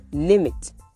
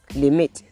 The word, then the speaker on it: limit: So, people too often will say limit. The right pronunciation is when the word sounds The stress is on the first syllable. But the wrong one is when you say limit. That is the wrong way to say it.